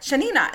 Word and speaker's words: Yanina: And I'm like, Yanina. And they'd be like, Y-A-N-I-N-A.